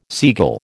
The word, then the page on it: seagull: American English